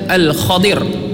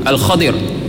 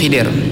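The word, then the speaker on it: Al-Khadir (الخضر): Yang kalau dalam riwayat hadis yang sahih, beberapa hadis yang sahih, di antaranya yang diriwayatkan oleh Ubay bin Ka'ab radhiyallahu an, ejaan dia adalah Al-Khadir. Kha-di-ru, Al-Khadir. Tapi orang kita biasa sebut Khidir.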